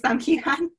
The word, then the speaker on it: Heughan: I'm saying it's Sam Heughan.